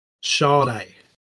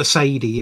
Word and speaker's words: Sade: One from, how do you say her name? Because I've been saying just Sade. – Sade. – Right, yep, one from her, Smooth Operator. – You can call her Sadie if you want, but okay.